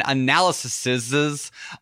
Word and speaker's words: analyses: Number three, I'm constantly running analyses on prospective properties. How do you say that? Analyses. I'm going to say analyses on prospective properties.